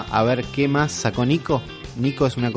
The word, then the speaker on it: Nyko: es un juego que, no, que todavía no le di Después de la Pokémon Direct Uli nos enteramos que la empresa Nico se viene con un montón de juguetitos que nos van a volver locos.